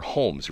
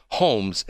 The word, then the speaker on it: Holmes: And they're not saying H-O-M-E-S. They're essentially saying H-O-L-M-E-S, but the L is silent.